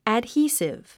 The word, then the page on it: adhesive: ədhíːsiv; アドヒィスィブ